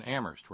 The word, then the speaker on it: Amherst: Home to folks like Kitty in Boston and Terry in Amherst, where only the H is silent.